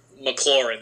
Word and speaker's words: McLaurin: McLaurin.